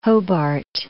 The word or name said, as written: Hobart